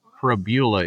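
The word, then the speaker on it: Pribula: Prabula. It does not rhyme with, this doesn't rhyme with fibula, like we thought.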